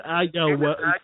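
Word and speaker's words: Oyelowo: This is a really hard name to pronounce. It's David Ayelowo.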